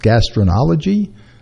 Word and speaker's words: gastroenterology: And Dr. Garson is from the Advanced Gastroenterology.